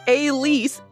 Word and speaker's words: Alice: Terrence, pronounced Elise, not Alice, like it looks.